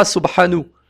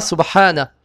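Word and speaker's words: Subhana (سُبْحَانَ): Donc on va toujours le prononcer avec la fatha. On ne dira pas Subhanu, Subhanu ou Subhani, avec la kasra ni avec la dhamma. On dira Subhana,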